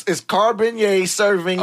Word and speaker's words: Cabernet Sauvignon: No, it's Cabernet Sauvignon.